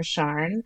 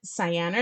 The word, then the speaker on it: Sian: I can't think of any reservations I have for the name Sian or Sharn. I think Americans will default to the Sian pronunciation. However you pronounce it, Sian or Sharn is a beautiful name and could rise in popularity due to the overwhelming popularity of names from the British Isles.